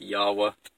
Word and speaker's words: Yahweh: Proper pronunciation should be something like Yahweh.